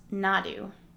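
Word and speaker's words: Naru: So she's a great tracker, and she's got, and her name is, it's pronounced Nadu, is how you say it. The R in Comanche sounds like a D.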